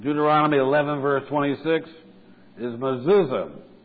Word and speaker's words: mezuzah: And the word looks something like this. M-E-Z-U-Z-O-T-H. Pronounced mezuzah. And they put these on the doorpost.